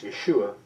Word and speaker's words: Yeshua: You know, the term Joshua, Jesus' Hebrew name was Joshua, pronounced Yeshua, and that means he will save his people.